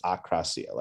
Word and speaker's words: akrasia: Yeah, I believe it's akrasia. Like when I've heard. Like akrasia, like a, like akrasia. The prefix, like against, like I think it's, and then krasia, I believe is will or is, so when I've heard it spoken about, I believe that's the correct pronunciation.